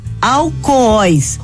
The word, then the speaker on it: alcoóis: Álcool no plural tem um acento no segundo O, alcoóis.